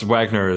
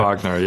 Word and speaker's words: Wagner: Now, is it pronounced Wagner, Zachary? – Yes, Wagner is just fine. – I was tempted to call you Wagner, but there we go. – Wagner, yeah.